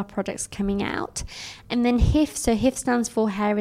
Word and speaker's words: HIIF: H-I-I-F or HIF? – HIF.